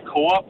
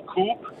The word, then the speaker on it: Coop: Øh, min forlod har haft det med at udtale Coop for Coop, på mm. trods af, at øh, de sådan siger det korrekt i alle deres reklamer.